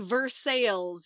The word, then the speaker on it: Versailles: or it's a place that you know how to pronounce, but we don't pronounce it right. Like Versailles. – Versailles, yes. We have a lot of places.